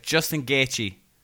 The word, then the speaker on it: Justin Gaethje: If Justin Gaethje... Did I say that right, Sean? – You did. You said it right.